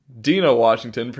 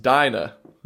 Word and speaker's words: Dinah Washington: Dina Washington, pronounced Dinah. Thank you, Carmen.